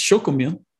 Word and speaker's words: Xocomil: It's a Mayan word. It's chocomil. – Chocomil. – Okay. – That's how it's pronounced.